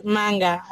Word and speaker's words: manga: It's manga. Is that the right way to say it? Manga. – Yeah, it is manga. That's right. Manga.